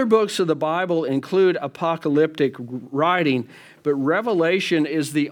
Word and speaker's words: apocalyptic: And so point number two, the book of Revelation is written in apocalyptic. It's a short A.